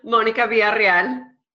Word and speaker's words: Monika Villarreal: Monica Villarreal.